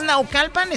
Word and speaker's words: Naucalpan: Es Naucalpan, Estado de México.